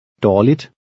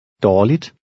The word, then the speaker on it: dårligt: Dårligt. Dårligt. – Remember that the G is silent when it follows a vowel.